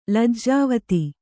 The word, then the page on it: ಲಜ್ಜಾವತಿ: lajjāvati